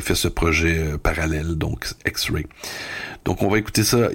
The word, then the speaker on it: EX2.RE: On dit qu'il faut prononcer X-Ray, donc prononçons X-Ray.